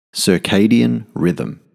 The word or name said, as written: circadian rhythm